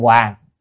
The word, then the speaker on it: Huỳnh: Là Hoàng hay Huỳnh cũng được, có người đọc âm Huỳnh, có người đọc âm Hoàng, âm miền Bắc thì đọc là Hoàng,